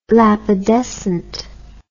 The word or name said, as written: lapidescent